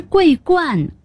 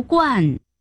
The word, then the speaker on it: 桂冠: 这我知道，我是说你读错了，那不是桂冠，是桂冠，冠军，当然得读冠。 – 冠军读冠没错，但是桂冠必须读作冠，第一声。